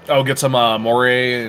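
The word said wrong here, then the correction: Māori: Am I saying that right? Moray or Moray? – Maori.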